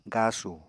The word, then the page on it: gasu: Apatani